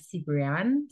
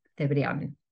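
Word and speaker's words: Cebrián: My surname is Cebrian. From American will be more, it will sound like Cebrian, something like that. – Right. – But the way we pronounce this name in Spanish is Cebrian.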